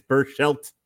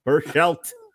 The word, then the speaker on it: Berchelt: He said it's Berchelt. Berchelt.